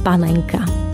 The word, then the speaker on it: panenka: Ak ide o jedlo pripravené z jemného, kvalitného mesa z dolnej časti chrbta ošípanej, vo výslovnosti používame tvrdé N, bravčová panenka. Toto slovo je prevzaté z češtiny a preto ho vyslovujeme s tvrdým N.